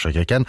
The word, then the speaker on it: Chaka Khan: Tout comme Chaka Khan, oui, ça se prononce Chaka Khan.